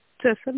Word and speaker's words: Цесар: Подскажите, пожалуйста, компания Цесор? – Цесар, да. – Цесар, простите за ударение.